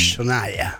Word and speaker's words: Shania: El nombre es Shania. – Shania. – Se escribe Gina, ¿no? – Sí, se escribe Shania.